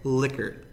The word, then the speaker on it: Likert: How do you say that? – Likert. – I think it's Likert, but you know, that's up for debate.